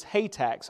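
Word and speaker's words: Hathach: I had to look up the place I looked to try to pronounce some of these names. Says you pronounce this Hatak, so I'm going to do my best to pronounce it, because he's an important person here.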